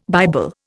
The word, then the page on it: Bible: Indian